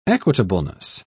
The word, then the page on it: equitableness: British English